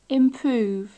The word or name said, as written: improve